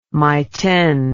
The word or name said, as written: mayten